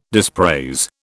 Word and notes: dispraise: British English

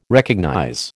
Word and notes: recognize: American English

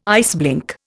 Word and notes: iceblink: Indian

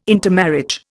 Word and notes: intermarriage: Indian

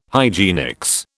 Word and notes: hygienics: British English

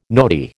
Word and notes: nodi: American English

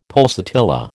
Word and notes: pulsatilla: American English

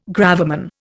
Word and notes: gravamen: Indian

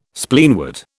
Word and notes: Spleenwort: British English

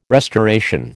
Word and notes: restoration: American English

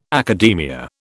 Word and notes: academia: British English

